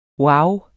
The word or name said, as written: wow